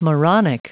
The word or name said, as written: moronic